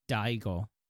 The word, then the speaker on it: Daigo: Daigo is like pronounced similar to Draco in Japanese because the sounds are so similar.